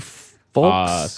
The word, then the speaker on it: Foulkes: Her name's Carly Folks. – Super hot. Carly Super hot. – I think her Twitter is full. It's spelled like F O U L K E S.